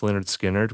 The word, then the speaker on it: Lynyrd Skynyrd: They put out their first record in 73, pronounced Lynyrd Skynyrd, which I think is like one of the great rock debuts of all time.